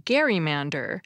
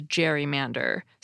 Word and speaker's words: gerrymander: And you'll notice that the term was originally pronounced gerrymander to reflect the pronunciation of Governor Gerry's name. But given that the first radio stations didn't start broadcasting until the early 1900s, there was no mass media to tell people how to pronounce the word they were reading. And people began to interpret the word as gerrymander, so that's what we call it today.